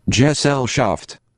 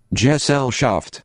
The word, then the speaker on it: Gesellschaft: Gesellschaft. – Oh. – Gesellschaft.